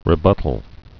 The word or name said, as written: rebuttal